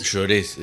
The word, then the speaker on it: Jaurés: Llorés, es bastante Llorés. Difícil a veces sí, saber cómo se pronuncia. Cómo se... Porque se escribe Jaurés, se pronuncia Llorés.